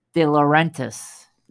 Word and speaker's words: DiLaurentis: How do you say that? DiLaurentis. There you go.